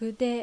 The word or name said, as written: Fude